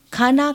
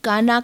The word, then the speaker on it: खाना: दक्षिण भारतीय खाना खाया का उच्चारण काना काया के रूप में करते हैं।